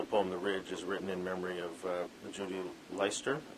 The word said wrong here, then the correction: Lester: Is that, is that how you pronounce her name? Uh, Lester. Lester, pardon me, and and Barry Lopez.